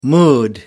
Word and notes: mood: American English

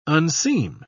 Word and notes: unseam: British English

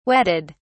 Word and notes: wetted: British English

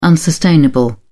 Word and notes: unsustainable: British English

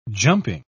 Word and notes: jumping: British English

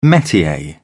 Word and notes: metier: British English

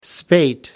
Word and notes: spate: American English

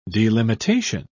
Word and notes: delimitation: British English

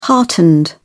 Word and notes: heartened: British English

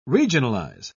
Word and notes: regionalize: British English

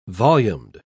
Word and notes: volumed: British English

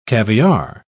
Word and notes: caviare: British English

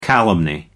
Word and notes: calumny: American English

